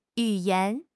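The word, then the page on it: yu3 yan2: tones 3, 2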